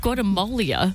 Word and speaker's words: Guatemala: Is it, how do you say it? – Guatemala.